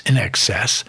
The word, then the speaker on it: INXS: And I promise you, Davey, I promise you, Terry, sit back and relax, because as soon as they spell it INXS, but it's In Excess off the Kick album, we'll stop.